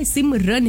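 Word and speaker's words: Simran: भाई रन नहीं है, सिमरन नहीं है, सिमरन है RAN.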